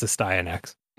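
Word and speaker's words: Astyanax: I've always wondered how to pronounce that. – I have a VHS from Jalico that it pronounces it. So it's Astyanex.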